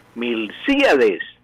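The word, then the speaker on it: Milcíades: Aquí de pronto el lechero, cualquier persona que llama, tiene el nombre de Milciades, le decimos Milciades, no es Milciades. Es Milciades. El acento es esdrújulo.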